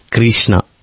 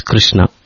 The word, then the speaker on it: Krishna: So I think just like when people break the word Krishna, also Kirishana, Swami used to not approve of it. – Yes, yes. Swami many times he corrected boys. It is not Krishna. It is Krishna. Something like that.